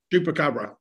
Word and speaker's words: Chupacabra: Chupacabra, yes. – Chupacabra, yeah.